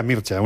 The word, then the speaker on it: Mircea: Sí, se pronuncia Mircea, uno que es muy friki de todos los rumanos, ya lo sabéis.